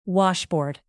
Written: washboard

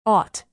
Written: ought

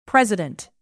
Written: president

